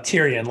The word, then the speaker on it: Tyrion: Is it Tyrion or Tyrion? I want to make sure I say it right. – Tyrion, like Game of Thrones.